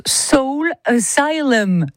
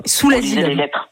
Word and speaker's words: Soul Asylum: Asylum, mais donc le nom du groupe se prononce Soul Asylum normalement. – Voilà. – Voilà. Mais bon, nous on disait. – On était très Sous l'asylum. Oui, c'est ça.